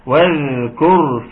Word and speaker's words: واذكر: لما عاوزنا بالذال واذكر في الكتاب إدريس. أختك كذبها لك هي ممكن تتقرأ كده واذكر، وده تبقى مشكلة كبيرة جدا. عاوزنا مرققة، واذكر في الكتاب إدريس. في ضم على الكاف تنطق ازاي؟ تنطق كو تو كو.